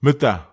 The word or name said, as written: meta